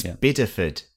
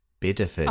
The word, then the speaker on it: Bideford: It's Biddeford. Biddeford.